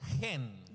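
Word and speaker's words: hen (חן): Es la palabra es chen. – The word is chen. – Que se pronuncia hen. – That you pronounce hen.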